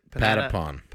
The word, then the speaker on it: Patapon: Panatapon. What is it? – Patapon. – Panana. – Yeah. So repeat after me. Pata, pata, pata. – Panenta. – Nope. Pata. Say it. – Spell it. – P-A-T-A. – P-A-N-T. – Nope. Pata. – Patapon. – Patapon. – Patapon. – There we go.